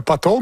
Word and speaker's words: potholes: Bravo, potholes, mais il faut bien prononcer le P.